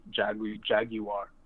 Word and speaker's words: Jaguar: Because, you know, whenever I think of Jaguars inside of football, I think of the Jaguar commercials for the cars and how they say Jaguar. – That's because that's how it's supposed to be pronounced. Let me just clarify that. – Oh, really? – It's supposed to be Jaguar. Just saying that's. I'm sorry. I love America, but you got that wrong.